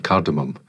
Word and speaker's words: cardamom: cardamom instead of cardamom, okay. um, because yeah. Oh, it, it's spelt with an M.